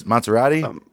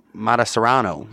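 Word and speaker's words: Mătăsăreanu: What did you say his name was? Maserati? – Matasarano.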